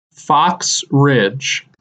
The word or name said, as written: Fox Ridge